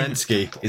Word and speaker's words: Zelensky: Zelensky is how you pronounce his surname.